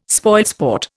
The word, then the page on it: spoilsport: Indian